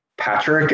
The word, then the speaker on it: Padraic: And so I'm like, it's Patrick, it's spelled. And she started writing, I'm like, no, it's spelled PADRAIC.